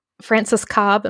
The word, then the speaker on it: Frances Cobbe: And then the other name that I, I got was Frances Cobbe.